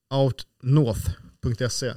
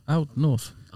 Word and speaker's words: Outnorth: Aldrig, vad säger jag? Alltså OUT? – Ja. Out North. – Out North. Ja.